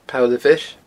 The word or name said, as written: Poll a Bhior